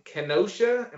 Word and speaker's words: Kenosha: The Bucks were officially protesting the police shooting of Jacob Blake, a black man from Kenosha.